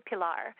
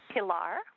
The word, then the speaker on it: Pilar: There's a lot of different pronunciations I'm happy with, or different ranges of the, some people call me kind of a, I say Pilar, which is sort of a little bit of an E sound at the beginning. Some people just say Pilar, which is fine. Some people say Pilar and just kind of run the P and L together, which is okay too.